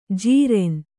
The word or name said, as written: ಜೀರೆನ್